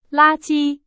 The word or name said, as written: 垃圾